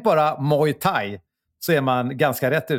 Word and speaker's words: Muay Thai: The Muay is pronounced like boy, but with an M instead of B. The Thai pronounced the same way as Thai, as in tie your shoes. Muay Thai.